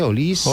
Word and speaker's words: Olise: Doué, Dembélé, Olise i Mbappé. – Sí. – Amb la Champions ens va quedar clar ja com l'havíem de pronunciar. Olise, Olise, Olise...